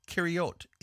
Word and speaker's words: Kiriath: First, the name Kiriath in English is actually pronounced Kiriot in Hebrew.